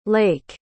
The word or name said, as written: laik